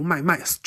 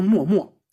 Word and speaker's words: 脉脉: 还有像呃血脉的脉啊，连起来脉脉。读麦麦，这是一种俗读。我们读古诗的时候，就一定会把它读成默默啊，盈盈一水间，默默不得语嘛。